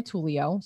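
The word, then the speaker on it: Tulio: find me on Instagram at Nina Tullio.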